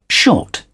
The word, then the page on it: short: British English